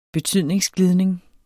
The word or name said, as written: betydningsglidning